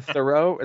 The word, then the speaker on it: Theroux: Justin Theroux, is that how you say it? – Yeah.